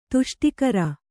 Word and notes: ತುಷ್ಟಿಕರ: tuṣṭikara; Kannada

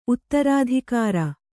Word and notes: ಉತ್ತರಾಧಿಕಾರಿ: uttarādhikāri

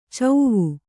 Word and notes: ಚೌವು: cauvu

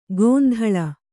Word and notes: ಗೊಂದಳ: gondaḷa

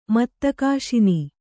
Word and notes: ಮತ್ತಕಾಶಿನಿ: Kannada

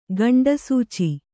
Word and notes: ಖಂಡಸೂಚಿ: khaṇḍa sūci; kannada

